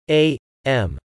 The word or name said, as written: a.m.